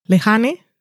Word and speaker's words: Lehane: Es la primera serie de Dennis Lehane, Lehane se escribe, con H, esa J que me ha salido ahí muy americana, mm, que es el, él es novelista, novelista de cosas como Mystic River, por ejemplo, ese estilo de cosas.